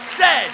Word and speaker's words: Z: I believe the French should whine about everything, the English should apologize, and that it is pronounced Zed, not Z, Zed, because that's how the Queen of England told us to pronounce it.